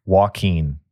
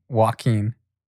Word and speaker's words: Joaquin: I'm going to tell you how to say it and you'll never forget. – Okay, how do you say it? – Joaquin. – Joaquin. Okay. It's like quinoa. – Yo. – It's like quinoa. – Yes. It's the reverse of quinoa.